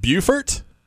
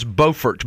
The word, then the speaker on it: Beaufort: Is it Beaufort? – No. – Beaufort. – Beaufort. – Beaufort's the one in South Carolina. – The South Carolinians mispronounce it. North Carolina, it is Beaufort.